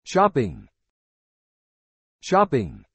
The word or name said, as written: shopping